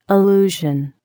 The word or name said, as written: illusion